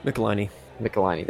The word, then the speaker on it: Michelinie: Michelinie, there you go.